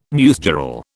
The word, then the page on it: newsgirl: British English